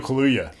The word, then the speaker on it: Kaluuya: from Big Little Lies was like, she was like crouched down on the ground and was like addressing Daniel Kalala? Is that how you say his name? – Oh, the Oscar ceremony. – Yeah. – Yeah. Daniel Kaluuya. – Daniel Kaluuya. It was like during the COVID Oscars.